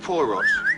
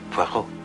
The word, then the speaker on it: Poirot: I've got a parrot with Mr. Poirot. – No. Poirot. It is pronounced Poirot.